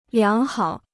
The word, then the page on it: 良好: liáng hǎo